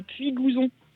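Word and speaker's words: Puygouzon: Je joue pour une association qui s'appelle Ramène ta chaise à Puy-Gouzon, si je ne me trompe pas. Est-ce que je l'ai bien prononcé? – Tu l'as bien dit, c'est dans le Tarn et c'est des gens très sympas qui s'en occupent.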